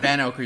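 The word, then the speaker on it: Vanocur: Van Oker. They say it like 7 times in the movie. – That's right, Van Oker. You're right.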